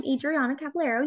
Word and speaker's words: Adriana Caballero: So I am Adriana Caballero. You got the name right. I mean, if we want to get to the Spanish pronunciation, but we won't get there.